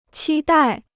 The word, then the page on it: 期待: qī dài